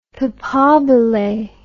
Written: papabile

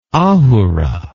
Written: ahura

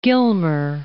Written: Gilmer

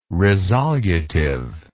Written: resolutive

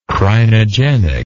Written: crinogenic

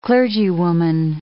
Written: clergywoman

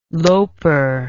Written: loper

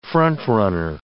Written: front-runner

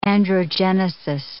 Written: androgenesis

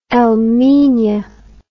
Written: El Minya

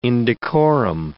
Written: indecorum

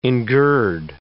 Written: engird

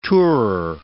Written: tourer

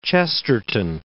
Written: Chesterton

